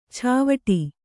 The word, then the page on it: ಚಾವಡಿ: cāvaḍi